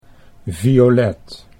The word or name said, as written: Violet